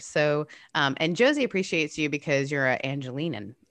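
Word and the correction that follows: Angeleno: Is that how you say it? – Angelino.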